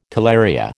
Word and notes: talaria: American English

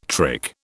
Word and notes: trick: British English

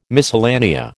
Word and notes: miscellanea: American English

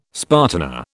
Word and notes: spartina: British English

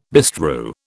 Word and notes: bestrew: British English

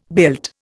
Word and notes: built: Indian

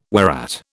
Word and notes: whereat: British English

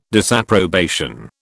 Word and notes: disapprobation: British English

